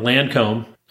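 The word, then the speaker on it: Lancôme: So from Lancome, hopefully I said that right.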